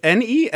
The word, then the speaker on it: Nesyamun: and mind my pronunciation, Nesamun, a 3,000-year-old ancient Egyptian. – Say that again. – It's Nesyamun.